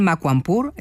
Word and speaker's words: Makwanpur: La sede está en Makwanpur, ¿he pronunciado correctamente? – Correcto.